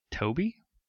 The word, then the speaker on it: Toebbe: Jonathan and Diane Toby. Sounds T-O-E-B-B-E. – Like, yeah, how you'd pronounce that.